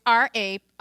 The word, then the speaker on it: Ra: It's R-A, pronounced Ra.